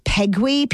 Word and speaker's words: Peguis: pronounced Pegui, P-E-G-U-I-S, who was a prominent First Nations leader in the 1800s, who came up and signed one of the first agreements.